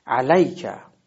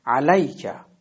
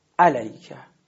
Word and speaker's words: علیکه: پس این کلمه اینجوری تلفظ میشه، علیکه، ببینین حرف لام علیکه، و حالت ناصحیح اون اینجوری میشه علیکه، که این اشتباه هست.